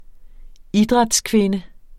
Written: idrætskvinde

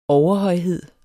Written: overhøjhed

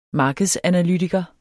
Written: markedsanalytiker

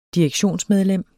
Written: direktionsmedlem

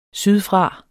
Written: sydfra

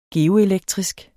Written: geoelektrisk

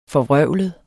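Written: FORVRØVLEDE

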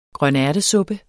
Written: grønærtesuppe

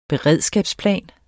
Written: beredskabsplan

